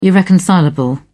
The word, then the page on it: irreconcilable: British English